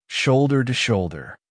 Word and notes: shoulder to shoulder: American English

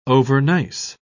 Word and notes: overnice: British English

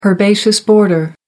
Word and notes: herbaceous border: American English

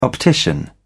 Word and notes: optician: British English

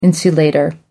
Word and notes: insulator: American English

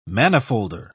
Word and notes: manifolder: British English

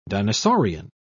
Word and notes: dinosaurian: British English

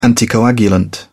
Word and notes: anticoagulant: British English

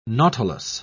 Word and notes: nautilus: British English

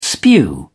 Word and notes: spew: British English

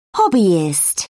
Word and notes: hobbyist: British English

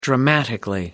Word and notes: dramatically: American English

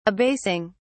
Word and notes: abasing: British English

